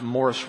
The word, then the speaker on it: Maurice: It looks like the name Maurice, M-A-U-R-I-C-E. – Выглядит так, немножко по-другому пишется Maurice. – But it's pronounced Morris Roberts.